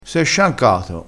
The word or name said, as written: sciancato